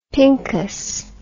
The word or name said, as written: pincus